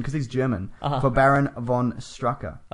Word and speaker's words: von Strucker: But he says it's F-O-N, so von Strucker. S-H-T-R-O-O-K-E-R. – Von Strucker.